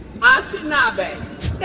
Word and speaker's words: Anishinaabe: Our land acknowledgements in Michigan recognize the state as the ancestral, traditional, and contemporary land of the Ashtabek Three Fighters Confederacy, including, say it again for me. Stand up. You do it better than me. Thank you. I'm big on names being pronounced right, so make sure I get it right. Anishinaabe? Anishinaabe. Thank you.